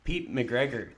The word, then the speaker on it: Pete McGregor: I am joined by the one and only Pete McGregor.